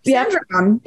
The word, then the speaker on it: Sandringham: Anyway, but some of the stories, that the biggest ones that came out of the volume 2 drop, was the meeting at Sandringham. I'm sure I'm saying it wrong. – Sandringham.